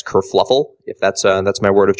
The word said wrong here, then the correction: kerfuffle: And I believe it's pronounced kerfuffle.